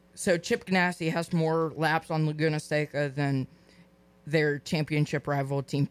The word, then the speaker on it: Laguna Seca: Laguna Seca.